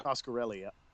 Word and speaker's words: Coscarelli: How do you say Don's last name? Don Coscarelli. – Coscarelli? – Yep. Coscarelli, yeah.